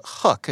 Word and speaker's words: Huq: Uh, and this is from a company called Hook. It's H U Q, and I hope I'm pronouncing that uh, correctly.